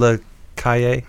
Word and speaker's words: La Calle: It's in La Calle.